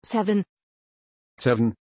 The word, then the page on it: Seven: German